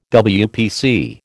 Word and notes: WPC: American English

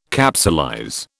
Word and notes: capsulize: British English

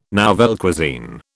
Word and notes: nouvelle cuisine: British English

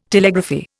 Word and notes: Telegraphy: Indian

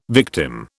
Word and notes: victim: British English

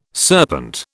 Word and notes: serpent: British English

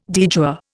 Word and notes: de jure: Indian

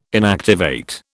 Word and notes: inactivate: British English

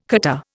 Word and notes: catarrh: Indian

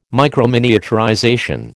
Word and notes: microminiaturization: American English

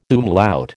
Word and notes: umlaut: American English

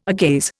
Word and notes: agaze: Indian

